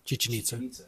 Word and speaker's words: Chichen Itza: Chichen Itza? – Chichen Itza.